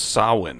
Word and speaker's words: Samhain: Mitch, you're saying Sam Hain? It's pronounced Samhain, actually.